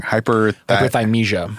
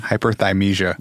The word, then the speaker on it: hyperthymesia: Oftentimes people who have I always fucking pronounce it wrong. – Hyperthymesia. – Hyperthymesia. – Yep.